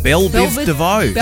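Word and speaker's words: Bell Biv DeVoe: But they're also bringing some friends with them, like Belle Bivadeau. – Belle Biv DeVoe.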